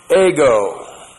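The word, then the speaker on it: ego (ἐγώ): The nominative plural is the personal pronoun, ego. E-G long O, ego.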